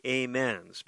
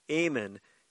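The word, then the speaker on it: Amen: It looks like, if you come from a religious background, that you want to call them the Amens, because it's A-M-E-N, but Amon is the more correct pronunciation.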